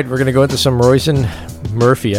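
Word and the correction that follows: Róisín: I know I'm saying her name wrong. – Roisin, okay.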